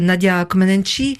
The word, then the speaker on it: Comăneci: Et ce corps, par exemple, il y a eu aussi celui de Nadia Comăneci.